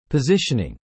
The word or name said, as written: positioning